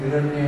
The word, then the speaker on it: Hiranya: but that you will learn, if you are right, when the Roman script is there, it is pronounced Hiranya and Savita. Then that Savita is ta, and it's not ta.